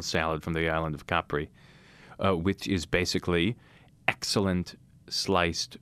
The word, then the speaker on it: Capri: A caprese is a salad that originates in the island of Capri, C-A-P-R-I. So, correctly pronounced Capri by many people,